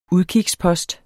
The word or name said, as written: udkigspost